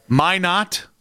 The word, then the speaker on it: Minot: Minot? – I just love that you guessed the T was silent and then tried to.